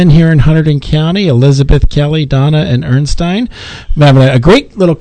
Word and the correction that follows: Ernestine: Did I say that wrong again? I apologize. Ernestine.